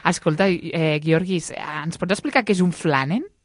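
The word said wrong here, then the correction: flâneur: No sé si ho pronuncio flaner. – Bé. Flaner? Sí.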